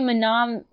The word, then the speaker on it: mnemonic: Because it's not spelled the way it sounds. – Johnny Mnemonic. Yeah. – But it's spelled with M-N, so you... – Yeah. – Mnemonic. – No. – Mnemonic. – Johnny Mnemonic. – Mnemonic. – The first M is silent.